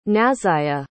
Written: nazayia

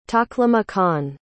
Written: taklimakan

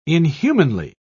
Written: inhumanly

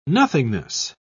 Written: nothingness

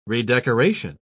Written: redecoration

